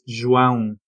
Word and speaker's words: João: Mr. Zhao. Is it okay, Zhao? How do you say it? – It's João. – John? – João. – Oh, that's interesting. How do you spell it? – It's J-O-A-O.